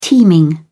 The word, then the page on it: teeming: British English